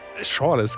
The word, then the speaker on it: Schorles: Wie spricht man es aus? Schorles? – Schorles, genau.